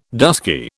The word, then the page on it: dusky: British English